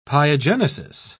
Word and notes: pyogenesis: British English

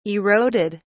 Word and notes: eroded: British English

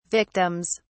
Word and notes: victims: British English

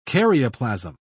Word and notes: karyoplasm: British English

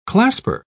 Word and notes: clasper: British English

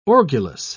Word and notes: orgulous: British English

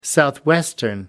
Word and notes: southwestern: American English

